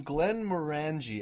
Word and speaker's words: Glenmorangie: We are drinking Glenmorangie, as I've been told it's pronounced.